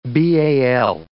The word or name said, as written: BAL